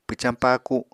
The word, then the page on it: pwcañ paaku: apatani